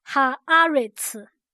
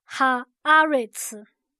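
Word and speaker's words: 哈 阿 瑞 茨 (הארץ): 我们现在要来看的字是这地，这地读音读作哈阿瑞茨，哈阿瑞茨，哈阿瑞茨。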